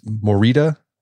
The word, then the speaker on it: Morita: It's Morita therapy, correct? – That's correct.